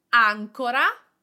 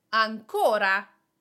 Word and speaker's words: ancora: For example, we have ancora, the accent is on the first A, and it means ensure, and ancora, the accent is on the O, and it means yet, still.